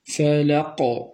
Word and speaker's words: الفلق: أعيد قل أعوذ برب الفلق، فنقول قل أعوذ برب الفلق، هذا بدون قلقلة وبالقلقلة، قل أعوذ برب الفلق فلق، واضح؟